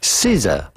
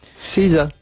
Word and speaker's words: SZA: S-Z-A. Yeah. S-Z-A, how do you go about pronouncing this? Well, it's pretty straightforward once you know. Caesar. Caesar. Caesar. Caesar. Pretty easy. Caesar.